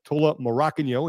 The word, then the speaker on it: Tola Morakinyo: Noah Olson, Tola Morakinyo, and yes, that is the right way to say his name, and he confirms it here.